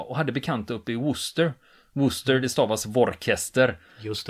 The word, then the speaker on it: Worcester: Ja, Worcestershire sås Ja, men det uttalas Worcester.